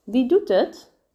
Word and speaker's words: Wie doet het: Real life pronunciation is Wie doet het?